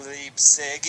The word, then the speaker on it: Leipzig: the pronunciation of Leipzig is Leipzig. – Like Heineken.